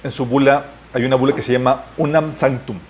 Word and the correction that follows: Unam Sanctam: unam Sanctam, perdón.